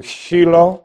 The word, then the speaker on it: Shiloh: That would be pronounced in Hebrew, Shiloh.